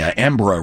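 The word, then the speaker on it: Embarras: Although, you know, I remember falling on my face with the Embra River because it's spelled E-M-B-A-R-R-A-S-S, I believe is the spelling. – Like embarrassed? – So I called it the Embarrassed River.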